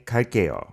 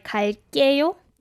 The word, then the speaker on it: 갈게요: And when it comes to this 갈게요, it has to be in a tensed way in the second syllable, like 갈게요. – Not 갈게요. – Yeah, not 갈게요, but 갈게요. – Yeah, it is spelled 갈게요, but you'll never hear a TIFF speaker say it like that, right.